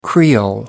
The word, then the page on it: creole: American English